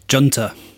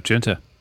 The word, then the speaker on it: Junta: And now that summer tour is over, we're getting back to it for backtracking for an album, which is called, how do you pronounce this album? – Junta. – Jonathan? – Junta.